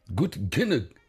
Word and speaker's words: Gut genug: Et pour dire en allemand, Gut genug. Mm-hmm. Je ne sais pas si je prononce bien. – Oui, ça va, ça passe près.